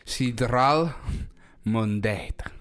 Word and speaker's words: Sidral Mundet: Sidral Mundet. – Yep, that's how you say it.